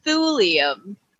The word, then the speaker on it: thulium: The most distinguishing feature of Turians is their metallic carapace, which contains trace amounts of thulium. I don't know if I said that right. It's T-H-U-L-I-U-M. – Sounds right to me.